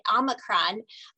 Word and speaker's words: Omicron: Omicron,